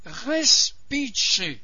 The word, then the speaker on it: Respice: Respice. La syllabe finale, c'est che. La syllabe qui porte l'accent tonique, c'est res. Et la deuxième syllabe, pi, c'est la syllabe atone.